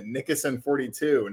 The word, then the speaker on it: Nickoson: So when I get on with Mo today and he says, you can follow him at Nickoson42 and it's pronounced like Nix with a K.